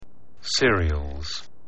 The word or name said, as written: cereals